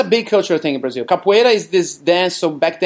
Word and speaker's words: capoeira: about capoeira. Am I saying that right? – The drink or the dance? – The dance, the martial art dance. Am I saying that? – Capoeira. – Capoeira. – That's it.